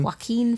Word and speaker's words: Joaquin: I think it's Joaquin Phoenix. Is that how you pronounce his name? – Joaquin Phoenix.